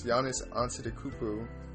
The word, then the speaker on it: Giannis Antetokounmpo: But, you know, he just wasn't able to bring it home because of this man and the Milwaukee Bucks, Giannis Antetokounmpo.